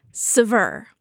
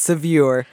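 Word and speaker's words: Saveur: Helen, how do you pronounce the second magazine in your bio? – Sever. – Sever. Sever. Oh, I would never have guessed that. Thank you. Yeah. I would have said Sevier.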